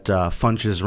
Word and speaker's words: Funches: How old were you when you first realized that Funches rhymes with bunches?